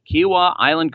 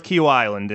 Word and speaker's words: Kiawah Island: Make sure that I pronounce it the way the locals do. How did I do right there? – That sounded good. I'm familiar with Kiawah Island, and you nailed it.